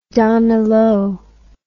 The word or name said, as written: Danilo